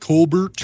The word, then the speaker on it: Colbert: So sorry, it's pronounced Colbert.